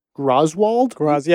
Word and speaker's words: Graswald: Graswald? – Yeah, it's spelled G-R-A-S-W-A-L-D. We think it's Graswald.